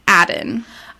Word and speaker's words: Aden: But it's spelled A-D-E-N. So I think it's Halima Aden. – I feel like she might pronounce it Aden.